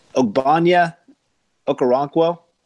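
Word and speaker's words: Ogbonnia Okoronkwo: Ogbonya Okoronkwo. – You said it right.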